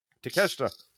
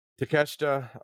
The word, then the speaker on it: Takeshita: Takeshita. – Takeshita.